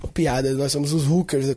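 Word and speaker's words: Hulkers: Inclusive, eu, eu tô chamando agora que a minha equipe são os Hookers. Né? Só que hookers é H-U-L-K-E-R-S, né? De Hulk, mas a pronúncia é igual ou quase igual a de hookers de prostituta, né?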